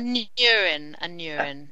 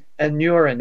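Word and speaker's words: Aneurin: Probably much more important is Lorelei Bevan, because of Anurin Bevan, please correct me, I've never heard Anurin pronounced. I've only read that. – Anurin. – Anurin. That sounds a little bit like urology, but I'm going to go with that. Anurin Bevan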